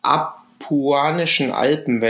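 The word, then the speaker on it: Apuanischen Alpen: Ähm, es gibt hier die Ab Kuanischen Alpen, wenn ich das richtig ausspreche.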